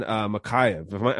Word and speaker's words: Makhachev: But Almeida, and then Makhachev. Am I pronouncing his name right? – That's correct. Yeah, Makhachev.